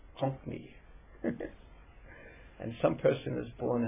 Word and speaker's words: Konkani: How do you say it? – Konkani.